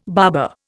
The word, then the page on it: barber: Indian